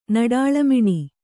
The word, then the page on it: ನಡಾಳಮಿಣಿ: Kannada